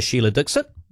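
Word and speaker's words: Sheila Dikshit: Her name was Sheila Dixit. – Was it? – Mm. – Oh, okay. – That was her name. – Oh, is that the correct pronunciation of it? – I believe so.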